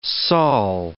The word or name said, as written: Saul